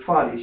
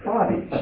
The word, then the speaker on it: Fady: I said, Fadi. She goes, what? I said, Fadi. She goes, what? I said, Fadi. She goes, how do you spell that? I said, F-A-D-Y.